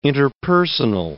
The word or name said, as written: interpersonal